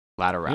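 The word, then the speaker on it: Lateralus: That's Lateralis. – Lateralis. In fact, I'm sending you a link. – Later, Alouse. – Where the band pronounces it. – I believe you. – Lateralis.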